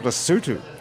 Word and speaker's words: Lesotho: It's called Lesotho.